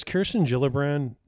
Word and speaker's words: Gillibrand: Has Kirsten Gillibrand, did I say her name right? – I think, yeah. – Has she said anything about Jim Jordan? – No, I don't think so.